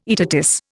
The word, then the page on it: aetatis: Indian